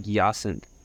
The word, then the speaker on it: Yacinthe: Yeah, it's like Yacinthe, like and then synthesizer.